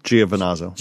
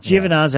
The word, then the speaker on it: Giovinazzo: How did you say his Giovinazzo. – Giovinazzo, yeah.